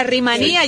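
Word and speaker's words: Rumanía: Oye, la Rimanía lleva acento en la I al final porque las terminaciones IA, IO se acentúan.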